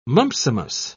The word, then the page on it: mumpsimus: British English